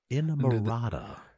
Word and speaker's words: Inamorata: How do you pronounce that song? Inamorata, James said.